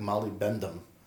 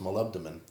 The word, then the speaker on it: molybdenum: It looks, when you read it, it looks like molybdenum, but it's pronounced molybdenum.